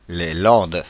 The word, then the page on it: Landes: Paris; [lɑ̃d]